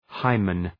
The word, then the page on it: hymen: {‘haımən}